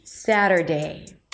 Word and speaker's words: Saturday: Three syllables. The first one is sa, sa. That's also the primary syllable, so it's going to be longer and higher in pitch. Satter, flap T and a schwa. Satter, not satter, but satter, satter. And then day at the end, Saturday.